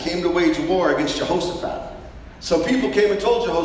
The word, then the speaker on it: Jehoshaphat: Jehoshaphat.